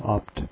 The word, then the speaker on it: Abt: Opt is spelled ABT. It's pronounced Opt.